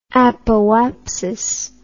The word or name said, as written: apoapsis